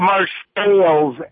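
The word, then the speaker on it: Marseilles: It's Marcelles, isn't it? – Marcelles, and Marcelles. It's because nobody in town can speak French.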